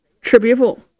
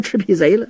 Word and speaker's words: 赤壁赋: 严茶伞自己写的，写着一首《赤壁赋》。冯君衡一瞧，你这赤壁贼写的不坏呀！来来来来，劳你大驾，我这有把扇子。这面是素的，你也给我写上这赤壁贼吧。雨墨不敢公开的顶了，小声嘀咕，嘿，连字儿都不认识，赤壁赋念成赤壁贼了，真不是东西。